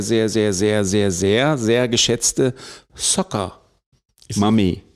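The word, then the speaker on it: Soccer Mommy: Und ihrem aktuellen Album, also nicht wie Saugen, Soccer, sondern wie Fußball. – Also Soccer. – Ah, ja. – Soccer Mami, so spricht man das aus.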